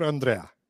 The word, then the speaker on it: André: No, I'm Andre because my mom is from Colorado, right? So Andrew was in the mix, but that got avoided because my Italian grandparents could never pronounce that W at the end. Andrea was in the mix, but that's a woman's name in the U.S. So option three was the French. André, it's actually it has the accent at the end, so it should be André, but you know, I am.